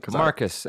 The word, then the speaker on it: Marcus: Did I say your last name right and everything? – Marcus. M-A-R-C-U-S.